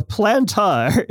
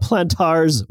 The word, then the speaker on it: plantar: So plantar is the medical term for the bottom of the foot. So a wart on the bottom of the foot is a plantar wart, just like a wart on the palm would be like a palmar wart. So it's not plantar's wart, for example.